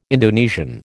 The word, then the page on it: Indonesian: American English